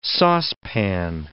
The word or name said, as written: saucepan